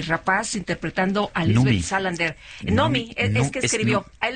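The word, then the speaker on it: Noomi: En sueco se pronuncia Nomi, Nomi Rapaz.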